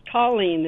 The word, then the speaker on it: Tallene: No, my name is Tallene. It's spelled with a T.